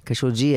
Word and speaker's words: Khashoggi: And the journalist, we discussed that 2 weeks ago, Khashoggi, I realized that everyone pronounces him Khashoggi. It's not, I used to say Khashoggi. Jaise Adnan Khashoggi hota tha na? Khashoggi hai ye.